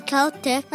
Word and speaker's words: Celtic: In America, we pronounce it Celtic. – Uh-huh. – But Miss Mora says Celtic like with the K. Why is that? – In Ireland, the word Celtic is typically pronounced with the K sound, similar to the British English pronunciation. But in America, the word is pronounced as Celtic.